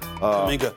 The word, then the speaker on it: Kuminga: Kuminga. – Cowabunga. I don't care how you say his name. – Kuminga's his name.